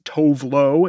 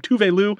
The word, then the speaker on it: Tove Lo: You may know her as Tove Lo if you pronounce it that way, but she is Swedish and she pronounces her name Tove Lo.